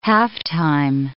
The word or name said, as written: halftime